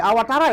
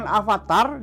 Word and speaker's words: awatara: bukan avatar, bukan juga autar. Autar. Nah. Awatara. – Iya. Karena dalam bahasa Sanskerta eh, V itu dibacanya W. W. Ya, jadi awatara, jangan Mungkin nah, salah tahu ini.